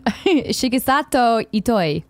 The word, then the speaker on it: Shigesato Itoi: A similar interview with Shigen Sato Ito, creator of Earthbound and found... What? Did I totally butcher it? – It's okay. Just keep going. – Do it. No, say it right. – Shigesato Itoi. – Thank you.